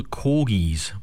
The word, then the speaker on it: Korgis: Corgis, but it's spelt K-O-R-G-I-S, but it sounds like the Queen's dogs.